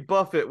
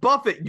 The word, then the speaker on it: Buffett: She thought Jimmy Buffett was an all-you-can-eat restaurant. It doesn't work because it's pronounced Buffett, you moron. He's not Jimmy Buffet.